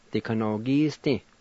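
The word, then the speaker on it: dikanogisdi: Number four, songbook, Dikanogisti.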